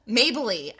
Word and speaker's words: Mabley: Mabley. I'm sorry, Mabley. I'm so sorry. Jackie Moms Mabley. I'm saying her name wrong. I dropped the Y. M-A-B-L-E-Y.